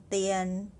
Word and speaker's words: 电: Number five, 电话电话电话 first syllable with consonant D for the sound the and then vowel is i a n. The ian, dian, dian, dian, dian, dian, fourth tone.